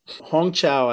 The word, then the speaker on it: Hong Chau: The Well, Hong Chau, I think is how you pronounce her name.